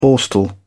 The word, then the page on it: borstal: British English